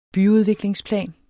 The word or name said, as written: byudviklingsplan